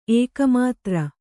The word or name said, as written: ಏಕಮಾತ್ರ